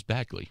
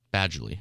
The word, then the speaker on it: Badgley: What Colts free agents do you see most likely to return and extra points for chaos if you choose Bagley? Badgley.